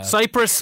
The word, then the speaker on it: Cyprus: Cyprus, Virus, it rhymes.